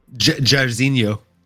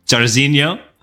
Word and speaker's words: Jairzinho: This is just, you know, Jairinho, or is that how you pronounce it? Jairinho? – Jairzinho. – Jairzinho. I forgot the Z, my apologies.